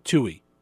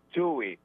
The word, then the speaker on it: Tuohy: It's like the number two in the letter E, Steve Tui. – Tui.